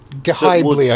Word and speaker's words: Ghibli: Ghibli, I think, is the official pronunciation.